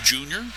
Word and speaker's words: Yunior: Tour spells his first name Y-U-N-I-O-R, but according to the A's folks, pronounces it Junior.